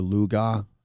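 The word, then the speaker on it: Cooper Lagos: But, I mean, starting quarterback Logan Bonner got hurt in the first quarter, and they put in this guy Cooper Luga. It looks like Lagos, but he pronounces it Luga.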